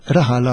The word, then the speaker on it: raħal: Però anke hemmhekk għandna argument ta' kontra għaliex jekk inti tgħid raħal, raħal għandha l-aċċent fuq l-ewwel sillaba u normalment il-Malti meta jqassar ma jqassarx billi waqa.